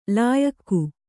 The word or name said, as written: ಲಾಯಖು